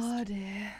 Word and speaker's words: Audie: Why do I keep saying Addie? Is it Audie? I don't know. I'm the worst. – Audie. – Literally every time I mention it, like I say it wrong.